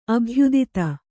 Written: ಅಭಯದಾತೆ

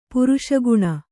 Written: ಪುರುಷಗುಣ

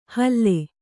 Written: ಹಲ್ಲೆ

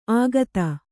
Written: ಆಗತ

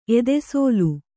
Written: ಎದೆಸೋಲು

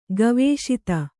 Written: ಗವೇಷಿತ

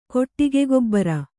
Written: ಕೊಟ್ಟಿಗೆಗೊಬ್ಬರ

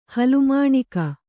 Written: ಹಲಮಾಣಿಕ

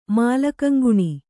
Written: ಮಾಲಕಂಗುಣಿ

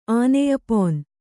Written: ಆನೆಯಪೊನ್

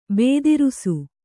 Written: ಭದ್ರಿಸು

